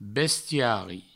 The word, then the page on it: bestiari: Provençal